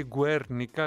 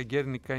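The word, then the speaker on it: Guernica: Αν δεν ξέρουμε την Γκέρνικα, ξέρουμε σίγουρα το έργο που το λένε πολύ Γκουέρνικα, αλλά Γκέρνικα είναι η σωστή προφορά.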